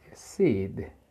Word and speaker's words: seed: En esta segunda tenemos que pronunciar la D, la D que va al final. Seed. ¿Sí la diferencia? Sí, seed. Esa D es suave, pero se pronuncia, hasta ahí.